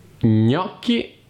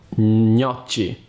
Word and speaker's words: gnocchi: Gnocchi e non gnocci.